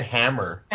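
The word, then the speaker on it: Hemmer: Ragnar's is Captain Picard, Spock, Dr. Phlox, Worf, Hymer. Is that how you say his name? I can't remember. – They say it Hammer.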